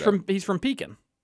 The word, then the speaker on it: Pekin: Thanks for saying it right, finally. – Pekin? I've always said Pekin. – No, you said Pekins. – No. – For the longest time, you both said Pekins.